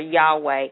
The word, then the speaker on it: Yahweh: When we've been through this one before, whenever you find the word Lord, L-O-R-D, in capital letters, that is the name Yahweh. In some traditions, or in Hebrew traditions, it may even be spelled capital Y-H-W-H, which is pronounced, as I said before, Yahweh.